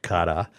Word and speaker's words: Qatar: The Brits don't end it with an R. They end it with an A-H, Qatar. – Qatar. – Right? So I think there's a lot of pronunciation. – Almost like Qatar. – Welcome back, Qatar. There we go.